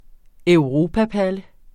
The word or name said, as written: EUROPAPALLE